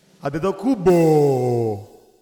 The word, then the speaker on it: Antetokounmpo: From Athens, Greece, Sepolia City, Alex, the baby, the family, Antetokounmpo. That's the right way to say on the last name, by the way.